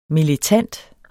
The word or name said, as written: militant